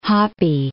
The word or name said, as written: Hoppe